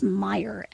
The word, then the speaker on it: Meijer: If you live in the Midwest, particularly the Northern Midwest, and these stores may be elsewhere as well, but we know them in the Northern Midwest as Meijer, and that's M-E-I-J-E-R. So it could be pronounced in a million ways by your screen reader, but the store is called Meijer.